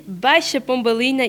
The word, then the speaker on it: Baixa Pombalina: On dit Baixa Pombalina e Bairro Alto.